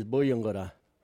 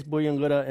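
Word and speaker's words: Buyungurra: Our local Mulgana word today is Buyungura. What is a Buyungura? Buyungura is a turtle. You can use Buyungura in this sentence.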